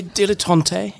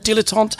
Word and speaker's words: dilettante: I'm a dilettante. Yes, that's right. That's how it's pronounced in English, not dilettante.